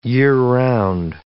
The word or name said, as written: year-round